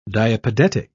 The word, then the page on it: diapedetic: British English